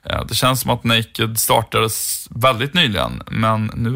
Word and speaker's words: NA-KD: Eh, det skrivs ju NA-KD, men det uttalas Naked.